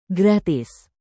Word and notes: gratis: Indonesian

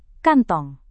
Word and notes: kantong: Indonesian

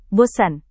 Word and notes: bosan: Indonesian

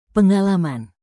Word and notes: pengalaman: Indonesian

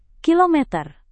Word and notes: kilometer: Indonesian

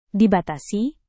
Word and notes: dibatasi: Indonesian

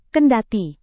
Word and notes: kendati: indonesian

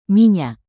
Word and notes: minyak: Indonesian

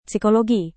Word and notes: psikologi: Indonesian

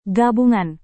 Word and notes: gabungan: indonesian

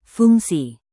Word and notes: fungsi: indonesian

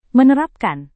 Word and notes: menerapkan: Indonesian

